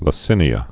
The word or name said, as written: lacinia